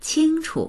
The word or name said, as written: qing1chu